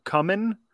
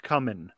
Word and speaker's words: cumin: During your guacamole thing, you, I think, mispronounced the name cumin, especially when you're talking about smut. You said cumin? – Yeah, it's pronounced cumin. – Sure. In America, especially south of Mason-Dixon, that's how you say it. But if you're trying to be, appropriate, you still say cumin. It's fine. You can say it both ways, I think.